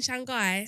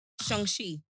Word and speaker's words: Shang-Chi: Have you got seen Shanghai? – Shang-Chi.